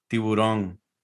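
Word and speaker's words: tiburón: Tiburon.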